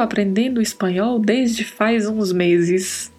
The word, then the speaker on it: meses: Atenção para a pronúncia aí, esse S entre duas vogais no espanhol não tem som de Z. Então pronunciamos meses, meses.